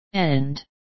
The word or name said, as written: and